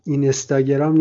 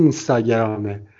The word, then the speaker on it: اینستاگرام: این استاگرام رو مثل بچه آدم تلفظ میکردم تا اینکه برادرم به من گفت نه این استاگرام نیست این اینستاگرامه بعد خودش ادامه داد گفتش که خب بعضیا میگن اینیستاگرام